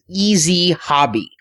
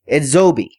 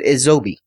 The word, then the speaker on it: EZHOBI: Everybody's pronouncing this company's name as Easy Hobby, because that's how you spell it, is E-Z-H-O-B-I. However, the guys at Yes Anime said it's pronounced Edzobi. – You sure they didn't just have an accent? – He was very, very clear, because I said, oh, this is Easy Hobby. He goes, when you're speaking it, pronounce it Edzobi.